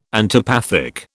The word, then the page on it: antipathic: British English